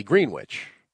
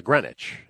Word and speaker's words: Greenwich: So it was Union Village, and then ultimately Greenwich, as opposed to Greenwich, and no one's ever explained why that is. Why we pronounce it Greenwich instead of Greenwich.